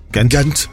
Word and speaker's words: Ghent: Ghent. – Yeah. – There's no need for the H to be in it, is there? – No, Ghent.